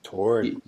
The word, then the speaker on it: Torryd: I want to throw out a crew that, in my opinion at the moment, is pretty heavily gang-related, and that's I don't know if I'm getting their name right, but I believe it's Torrid. – Torrid, yep. – T-O-R-R-Y-D, kind of a tough name to pronounce there, boys, but I dig what you're doing.